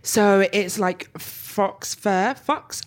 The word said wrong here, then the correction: faux: I always can never pronounce that shit. – Faux fur.